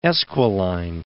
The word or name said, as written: Esquiline